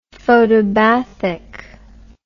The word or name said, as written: photobathic